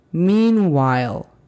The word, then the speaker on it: meanwhile: Si decimos la pronunciación fonética que te acabo de dar con la pronunciación de la L equivocada, se escucharía meanwhile. Meanwhile. Se interrumpe y no se entiende exactamente.